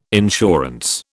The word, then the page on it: insurance: British English